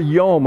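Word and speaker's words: yom (יום): In the creation account, Moses uses a specific word to describe the 6 days, D-A-Y-S, the 6 days of creation. And he uses this word in the Hebrew, yom. We would pronounce it in English, Y-O-M, yom.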